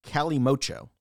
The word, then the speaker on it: Calimocho: So another way you can do this is mixing red wine and Coke, which is spelled very strangely, but pronounced Calimocho.